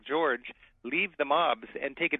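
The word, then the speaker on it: György: As in English, where PH means an F sound, in Hungarian, GY means the J sound. So MAGYAR is Magyar. – Magyar, or GYORGY is George.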